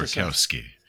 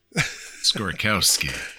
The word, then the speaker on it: Skorkowsky: Skorkowsky. Skorkowsky.